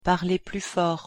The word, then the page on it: parlez plus fort: French